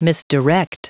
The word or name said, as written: misdirect